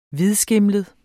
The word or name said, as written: hvidskimlet